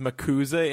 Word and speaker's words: MACUSA: My bit on it is kind of – it's very interesting that she would choose to have – the Mac USA, or actually she corrects everybody with the – she gives everybody a phonetic pronunciation here and says it's commonly pronounced as Macusa.